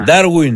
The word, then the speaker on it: Ντάρουιν: Ναι, Ντάρουιν. – Θα κάνουμε για ποιο μα. – Ντάρουιν.